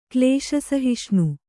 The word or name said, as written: ಕ್ಲೇಶಸಹಿಷ್ಣು